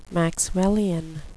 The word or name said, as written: Maxwellian